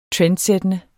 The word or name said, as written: trendskabende